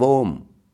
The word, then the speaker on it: Om: In Sanskrit grammar, A and U coalesce to form owe. Thus, a, u, and m, a, u, and m together is pronounced as om. Even the mute can produce the sound of om.